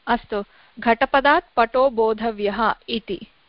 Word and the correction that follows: बोद्धव्यः: बोधव्य इदानीम् अत्र किञ्चित् उच्चारणं सम्यक् करणीयं. बोधव्यः न, एकवारं प्रयत्नं करोतु. – बोद्धव्यः, बोद्धव्यः एवम् उच्चारणं संस्कृतवाक्य मन्त्रस्वरूपाणि वाक्यानि समीचीनम्.